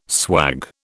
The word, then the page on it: swag: British English